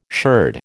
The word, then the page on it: sherd: American English